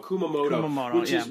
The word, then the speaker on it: Kumamoto: Kumamoto? – Oh, it's in front of me, but I got to get the right I think it's here. From Kumamoto. – Kumamoto.